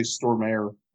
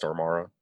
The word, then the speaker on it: Stormare: I have never said his name right. I always say Stormare. Right, yeah. Um, I always did too, and I looked it up just to see, and yeah, it's Stormara.